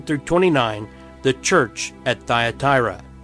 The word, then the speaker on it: Thyatira: That's, if you didn't know how to pronounce that, Thyatira.